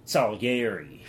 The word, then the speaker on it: Salieri: You pronounce Salieri like, Salieri. Antonio Salieri.